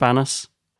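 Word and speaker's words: Banús: He was like, it's pronounced Banus.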